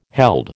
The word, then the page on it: held: American English